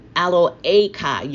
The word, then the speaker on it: Eloheka: Eloheka is not the hey, the H is silent. I'm sorry, I keep saying that. It's Eloheka, Yahuwah Eloheka, which brought you out of the land of Mizraim.